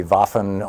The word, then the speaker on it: Waffen: Well, W's are pronounced like a V in German, but so I believe it would be Waffen, or maybe they've anglicized it.